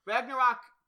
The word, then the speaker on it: Ragnarok: Ragnarok.